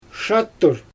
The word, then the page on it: ʃattur: Xyzyl